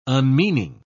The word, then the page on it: unmeaning: British English